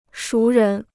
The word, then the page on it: 熟人: shú rén